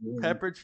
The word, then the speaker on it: Pepperidge: Pepperidge Farms.